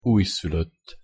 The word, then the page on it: ujszulott: Hungarian